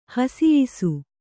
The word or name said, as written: ಹಸಿಯಿಸು